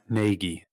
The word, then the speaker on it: Nagy: Yeah, and it rhymes with Aggie. Oh, yep. Matt Nagy.